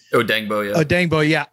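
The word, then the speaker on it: Odeyingbo: I don't think Dayo Odiengbo, is that how it's pronounced? – Odiengbo.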